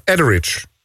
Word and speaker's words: Etheridge: Melissa Etheridge. Zeg ik het goed? – Etheridge.